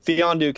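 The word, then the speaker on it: Mfiondu: But Mifondu? – Mifondu. Mifondu? Is no, it just Fiondu? The M at the beginning throws everybody off. – Fiondu? – Yeah. – Fiondu Cabangeli, I think is rookie mistake.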